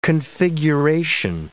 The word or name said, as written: configuration